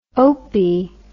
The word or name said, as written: opie